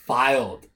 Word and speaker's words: Fylde: Filed RFUC mm-hmm. – in England, one of the old traditional powerhouses. Well, little do you know. They're spelled F-Y-L-D-E, but they're pronounced filed.